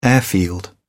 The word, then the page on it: airfield: British English